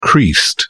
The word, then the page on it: creased: British English